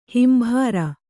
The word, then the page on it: ಹಿಂಬಾರ: Kannada